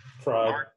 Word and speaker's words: Fried: Fried. It's like a fried egg.